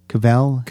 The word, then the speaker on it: Cavill: Cavill? – Cavill.